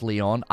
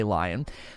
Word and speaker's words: Lyon: So especially when the interview with Jeff Keighley showed that the Arcane Lion team, or Leon team, it's actually pronounced Leon, I always want to say Lion, that team is the team that will be working on it.